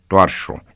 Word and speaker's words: tovarășul: De fapt, cuvântul tovarășul se pronunța în realitate toarșu, iar prescurtarea tov, T-O-V, punct, devenise articulată, tovul și tova.